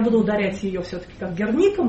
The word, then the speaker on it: Герника: Мы говорили о том, что в России сложилась традиция Герники, просто потому что это 30-е годы, когда не у кого было спросить, как правильно. Это топоним, это город Герника, поэтому повода менять ударение тут нет никакого.